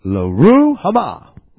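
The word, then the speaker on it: Lo-ruhamah: So, Lurama, I didn't say it right again, it's Loruhama.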